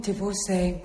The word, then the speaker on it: divorcee: What's wrong with divorcee when it rhymes with Beyoncé?